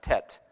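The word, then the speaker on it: Teth: Specifically, in the section that I think is pronounced Tet, in verse 65.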